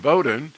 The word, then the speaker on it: Woden: But the fourth idea is that Santa Claus also absorbed elements of a Germanic god, Woden, I guess you pronounce a W like a V in German, Woden, associated with a pagan midwinter event of Yule, and led the wild hung, which was a ghostly progression through the sky.